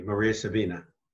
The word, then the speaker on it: Maria Sabina: And I think I'm pronouncing that correctly. – Absolutely, Maria Sabina.